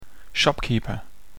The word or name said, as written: shopkeeper